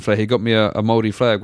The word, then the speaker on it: Māori: Māori. M-A-O-R-I. If you were to say how it's spelled, it's like Maori or Māori. – Gotcha, Māori. – You kind of roll that R.